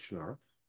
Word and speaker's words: Shannara: And because of Doug Terry Brooks, the international bestselling author of the Shannara series, he pronounces it different. He's wrong, it's Shannara.